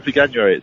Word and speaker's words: Veganuary: I mean, you're doing something to promote Veganuary, if that's how you pronounce it, Vegan January. – Yeah, well, I've been. It's called Veganuary.